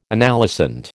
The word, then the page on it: analysand: American English